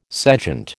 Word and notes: sejant: American English

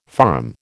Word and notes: farm: American English

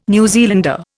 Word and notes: New Zealander: Indian

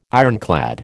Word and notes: ironclad: American English

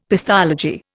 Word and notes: pestology: Indian

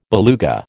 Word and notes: beluga: American English

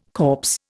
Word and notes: corpse: Indian

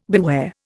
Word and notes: beware: Indian

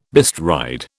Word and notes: bestride: British English

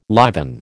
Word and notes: liven: American English

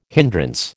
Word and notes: hindrance: American English

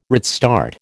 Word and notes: redstart: American English

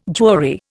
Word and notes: jury: Indian

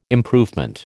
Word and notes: improvement: American English

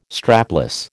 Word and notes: strapless: American English

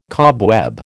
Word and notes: cobweb: American English

